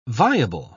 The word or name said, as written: viable